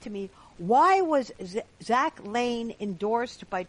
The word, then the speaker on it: Lahn: Is that how it's, well, it looks like Lahn. It's pronounced Lane. – Okay. – It's L-A-H-N, but it's okay. Pronounced like Lane.